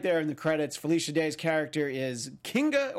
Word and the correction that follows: Kinga: or just Kinga Forrester. I think I had a little too hard G there. – Kinga.